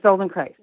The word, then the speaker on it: Feldenkrais: So it's ironic that you're on today to talk a little bit about, is it Feldenkrais? Is that how you say it? – Is it? Yes, Feldenkrais. Yeah. – Okay. So with rice. Yeah. – Okay, good. That's a good way to remember it.